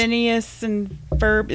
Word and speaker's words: Phineas and Ferb: A Phineas and Ferb. Am I saying this correctly? – Right, you are.